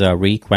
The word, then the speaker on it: Reek: You also have Sam Rockwell, Alfie Allen, who plays Reek, rhymes with Meek.